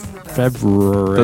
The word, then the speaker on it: February: If you if you say it correctly with the R in the right, right place, it's February.